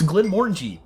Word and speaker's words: Glenmorangie: It's actually, as I learned many years ago, it's Glenmorangie. – Morangy? – Yes. It okay. rhymes with orangey.